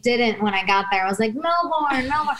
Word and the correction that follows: Melbourne: And then everybody's like, it's Melbourne.